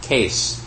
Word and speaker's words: kesu: this is bell is actually called a case, which is spelled K-E-S-U, and the U is silent.